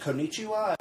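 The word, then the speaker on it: konnichiwa (こんにちは): Did I say that right? Is it konnichiwa? – It was perfect.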